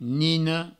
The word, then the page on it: nina: nino